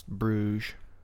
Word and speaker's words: Bruges: There's an S in it, isn't it? Isn't there? – There is, but it's pronounced Bruges.